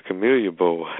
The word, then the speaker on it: Camellia Bowl: Let's talk about is this the Camilla Bowl? Bowling Green at South Alabama. Is that the correct pronunciation of that? – Yep, the Camellia Bowl.